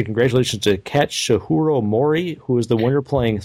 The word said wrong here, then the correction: Katsuhiro Mori: Well, how do I say it correctly, then? – Katsuhiro Mori. – I swear that's exactly what I said. – No, you went Katsuhiro Orgasm Mori.